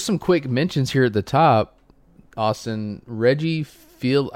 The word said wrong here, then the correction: Reggie Fils-Aimé: I never know how to say his name. It's like Reggie Fils-Aimé. – Fils-Aimé. Okay.